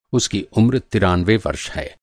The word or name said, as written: तिरानवे